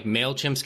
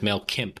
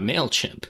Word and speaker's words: MailChimp: And some lady was reading off a card and didn't know how to pronounce MailChimp. She pronounced it as MailKimp.